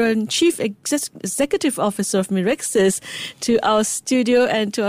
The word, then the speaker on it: Mirxes: So Mirexis, six syllables, stand MIR, stand for microRNA, which is the technical thing we work on, very small type of RNA in our body.